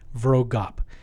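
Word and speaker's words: Vroegop: Difficult last name to pronounce, but V-R-O-E-G-O-P-P, Vrogop.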